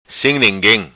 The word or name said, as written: Sim Ninguim